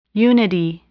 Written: unity

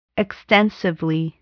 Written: extensively

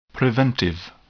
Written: preventive

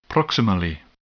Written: proximally

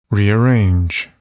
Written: rearrange

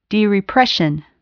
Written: derepression

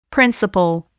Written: principal

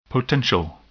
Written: potential

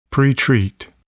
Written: pretreat

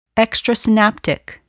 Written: extrasynaptic

